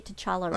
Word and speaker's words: T'Challa: I would say T'Challa, right? – How do you say that? – T'Challa. – No, you got it right.